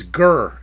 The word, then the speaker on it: ger: The Hebrew word there is ger, G-E-R. That's the transliteration. That's how you pronounce it.